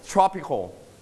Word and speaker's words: tropical: You stress on the first syllable. Tropical.